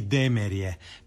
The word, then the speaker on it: Demerje: Ne, jedino je pravilno Jakovlje i Demerje. Pitajte ljude tamo i kad god možemo, onda to prihvaćamo i izgovaramo tako.